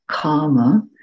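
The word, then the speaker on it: kāma: The one that I used for sense desire, kama, is K long A, so that's an A with a bar over it, M A, kama.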